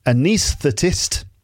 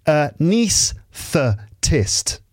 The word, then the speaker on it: anaesthetist: It's the anaesthetist. There's a difficult word. Can you pronounce that? Anaesthetist. A niece, yes, like a niece and a nephew.